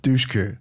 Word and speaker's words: Dushku: Is that how you say it, last Dushku, name? Yeah. – Dushku.